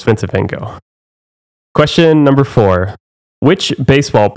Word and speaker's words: Van Gogh: That's how everyone else pronounce it. – Really? – The French pronounce it Van Gogh as well.